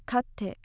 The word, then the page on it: katte: aussage